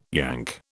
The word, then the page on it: Yank: British English